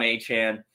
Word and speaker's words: Achane: I didn't make the overlay with his face on it, but I saw a good stat about Devon Achane, and see, I said it right this time.